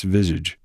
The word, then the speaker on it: visage: And visage, which I want to pronounce like mirage, but it's visage.